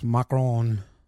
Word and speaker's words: macaron: Anyway, he goes macaron. That's how you say it.